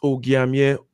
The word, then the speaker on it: Ogiamien: It's Ogiamien. The hard G part is the part to remember, and the rest just kind of flows with the...